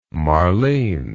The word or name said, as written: Marlene